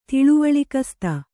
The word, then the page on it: ತಿಳಿವಳಿಕಸ್ತೆ: tiḷivaḷikaste